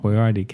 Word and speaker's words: Boyardee: And that's where Chef Boyardee came from.